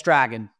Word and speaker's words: Dragan: And it's, yes, Dragan, like a dragon. He's Serbian, and it's pronounced differently, but he goes by Dragan.